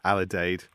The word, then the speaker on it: Alidade: Recent projects you've worked on include Alidade. Am I pronouncing that reasonably correctly? – Alidade. – I was pushing it up a bit. – Yes. – With Francesca Dare, Richie Chandler.